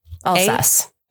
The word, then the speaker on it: Alsace: E-B-L-I-N. Eblin Fouche. F-U-C-H-S. – I'm sorry, what? – Eblin Fouche, sparkling wine from the I don't know how to say this. I don't think I've ever seen this region before. Alsace? – Alsace. That's where my family is from.